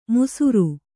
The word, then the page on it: ಮುಸುರು: musuṛu